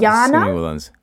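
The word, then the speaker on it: Jana: You pronounce the J with a Y. – So not Yelena. – Not Yelena. – Yana.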